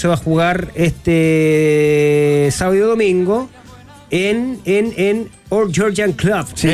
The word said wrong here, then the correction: Old Grangonian: En el Old Grangonian Club. – ¿Cómo se dice? – Old Grangonian.